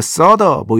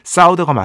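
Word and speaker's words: Souther: 이거 서더 뭐 이렇게 발음해야 된다고 하시는 분들이 가끔씩 있는데 사우더가 맞습니다.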